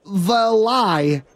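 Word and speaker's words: Varlai: It's V-A-R-L-A-I, but the R is silent. – Valai. – Okay. – Rennell Valai can be either something really bad, like an undiagnosed liver condition.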